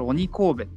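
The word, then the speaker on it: 鬼首: 首温泉って書いて、うん、これ鬼神戸って読むんですよね。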